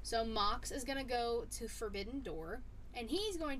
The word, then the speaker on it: Goto: Hirooki Goto. – Goto. Okay, I didn't know how to pronounce his last name, and I didn't want to be wrong.